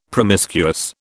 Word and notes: promiscuous: British English

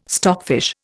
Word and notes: stockfish: Indian

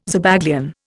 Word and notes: Zabaglione: Indian

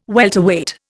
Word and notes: welterweight: Indian

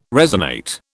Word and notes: resonate: British English

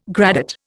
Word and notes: gradate: Indian

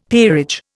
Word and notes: peerage: Indian

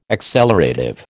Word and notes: accelerative: American English

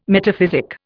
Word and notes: metaphysic: Indian

